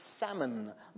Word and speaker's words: Salmon: S-A-L-M-O-N in English is a fish, which is salmon. The L is silent. But in the genealogical name, and I even checked this online for the correct Hebrew pronunciation, there was a man named Salmon. S-A-L, you pronounce the L there phonetically.